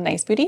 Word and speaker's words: Nice Boudie: So our company name is called Nice Booty Studio. If you Google us, we will come up. It's spelled Nice, N-I-C-E, Booty, B-O-U-D-I-E, like boudoir.